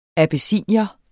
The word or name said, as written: abessinier